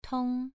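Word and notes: tong: tone 2

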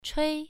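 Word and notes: chui: tone 1

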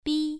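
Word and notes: bi: tone 1

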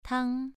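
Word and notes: tang: tone 2

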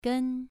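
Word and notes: gen: tone 2

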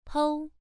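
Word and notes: pou: tone 1

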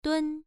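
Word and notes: dun: tone 1

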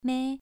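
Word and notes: mei: tone 2

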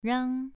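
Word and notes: rang: tone 2

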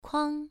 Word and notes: kuang: 1

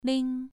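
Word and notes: ling: tone 2

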